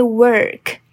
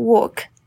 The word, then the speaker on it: work: 这边一定要帮你们补充一下，我刚刚念的单字“ 工作 ”（work），work，它是 work，直接 work，没有任何 o 的音哦。我听到很多人会念成 go to work，那不是这样发音的，所以它是直接卷舌 er，所以 work，work。